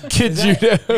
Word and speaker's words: Cejudo: That's how you say that word. – Cejudo. – You find America, we're going to say your name like America. – Cajudo.